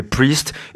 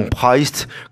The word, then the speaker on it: Priest: Alors vous prononcez bien, s'il vous plaît, Priest et non Priest, comme on l'entend toujours dans les bouches françaises.